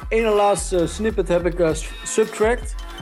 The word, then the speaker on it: SBTRKT: Zijn naam schrijf je als S-B-T-R-K-T. Maar spreek je uit de Subtract.